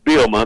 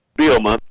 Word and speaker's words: Bielema: It's pretty close. It's Bielema. The second he's a little silent, though. Bielema.